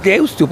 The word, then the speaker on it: Deusto: Es Deusto, porque se escribe con U final.